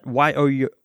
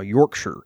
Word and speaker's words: Yorkshire: However, if one calls Yorkshire his or her home, it is pronounced Yorkshire. And he says that Yorkshire. So that's the pronunciation style, Yorkshire. It's the same with Lancashire. It's pronounced Lancashire. And the Queen, she lives at Buckingham Palace, but it's not Buckingham, it's Buckingham.